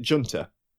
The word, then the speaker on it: junta: A good example being the board game and, you know, the Serb Junter, which, strictly speaking, is technically correct if you call it Junta, because the English term for it doesn't have the soft J sound the Spanish does.